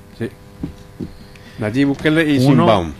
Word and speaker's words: Sheinbaum: Sheinbaum. – Sheinbaum, sí, la mexicana.